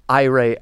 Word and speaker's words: Aire: Let me ask you about Aire as a title. – Sure. – It means air, right? – That's right, and you pronounce it very nicely.